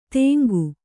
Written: ತೆಂಗು

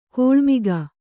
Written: ಕಿಳ್ಮೊಗ

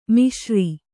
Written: ಮಿಶ್ರ

